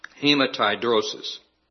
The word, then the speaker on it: hematidrosis: But it's a condition that's called, and I'm going to be real careful how I pronounce this, hematidrosis.